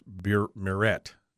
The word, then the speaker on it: Marit: Marit is pronounced Burette. Burette? Marit is Murette. Murette is pronounced Burette, but with an M.